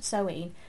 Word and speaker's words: Samhain: It's spelt Samhain, but it's pronounced Samhain, looking at you deliberately supernatural.